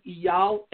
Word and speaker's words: Eyal: You can call my tour agent, Eyal Evan. His name is spelled E-Y-A-L. Eyal Evan, E-V-A-N is last name, his first name, Eyal, E-Y-A-L, Eyal Evan.